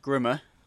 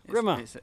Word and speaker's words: Grimme: Talk to us about what you've set up here, the lovely bright red with Grimm, not fairy tale, but Grimm, G-R-I-M-M-E, in front of it. – It's pronounced Grimmer. – Grimmer.